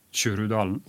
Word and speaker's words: Kjurudalen: det som jeg tror uttales Kjurudalen. – Ja, det rett.